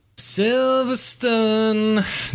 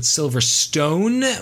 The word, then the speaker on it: Silverstone: Silverstone. Not to be confused with Silverstone, which is a mispronunciation of an otherwise wonderful place.